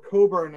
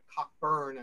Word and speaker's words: Cockburn: Certainly from an American perspective, Cockburn, or Coburn as the British pronounced it, but Cockburn as we Americans did, receives the lion's share of that approbation.